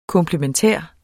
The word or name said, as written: komplementær